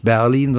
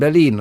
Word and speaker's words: Berlin: Nicht, aber der kam da gerade lang und das heißt eben Berlin und nicht Berlin, sondern Berlin. Deswegen heißt es Berlin und nicht Berlin.